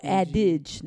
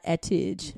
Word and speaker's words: adage: Oh, adage, not atige.